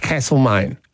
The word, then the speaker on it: Castlemaine: Locals look at you and go, it's Castlemaine. – And that would be the original pronunciation of castle.